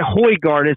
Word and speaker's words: Højgaard: You mentioned Nikolai Højgaard, or Højgaard. How do you say that? I liked how you said that. – Nikolai Højgaard is how I've heard it pronounced on the whenever they do the DP World Tour coverage. So unless they've been getting it wrong, I think I'm pretty close.